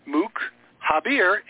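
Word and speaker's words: Moukhaiber: Marco Mukaber, spell it right, M-O-U-K, like Mook, you know, like a jerk. Mook Haber, H-A-I-B-E-R.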